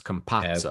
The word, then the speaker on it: Campazzo: Campazzo. Campazzo. – I read online, it's like pizza, like how you say the Z's in pizza. So it's Campazzo.